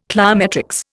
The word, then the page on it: cliometrics: Indian